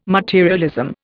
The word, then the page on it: materialism: Indian